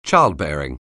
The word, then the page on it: childbearing: anglais